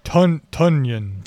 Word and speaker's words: Tonyan: Tonyan. – Tonyan. Robert Tonyan. – Like Funyan, but Tonyan.